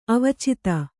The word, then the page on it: ಅವಚಿತ: avacita